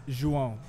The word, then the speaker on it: João: It's João. It's spelled J-O-A-O. – J-O-A-O. And it's pronounced João.